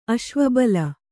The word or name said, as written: ಅಶ್ವಬಲ